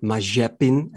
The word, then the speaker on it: Mazepin: We had three Formula 2 drivers entering the scene this season, Tsunoda, Mazepin, and or Mazepin, as it's apparently pronounced in Russian, and Schumacher.